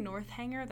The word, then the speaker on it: Northanger: I really want a pretty copy of Northanger Abbey. I've been saying Northanger. That's not how you say it, I think. I think it's Northanger.